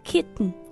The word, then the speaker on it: kitten: 对，在美式发音当中呢有这么一个小技巧叫做鼻腔爆破，所以这个 kitten 它会发成 kitten。